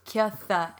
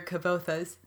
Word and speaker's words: Kvothe: Well, I decided it's not Q-U as in qua, because when he said it sounds like this, it's not like qua. I think it's the actual sound of a Q-U, like kya, kya. So then it's just Kvothe, and the like the, you know, some languages pronounce these.